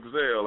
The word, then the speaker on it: Zell: My name's Doug Zell, like Denzel, but with Duck Zell.